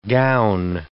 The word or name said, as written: gown